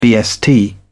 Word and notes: bst: British English

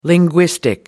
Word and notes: linguistic: American English

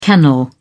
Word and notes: kennel: British English

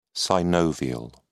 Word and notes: synovial: British English

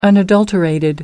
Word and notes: unadulterated: American English

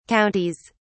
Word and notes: counties: British English

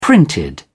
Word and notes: printed: British English

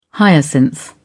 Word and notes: hyacinth: British English

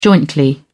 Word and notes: jointly: British English

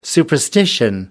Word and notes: superstition: American English

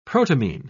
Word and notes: protamine: British English

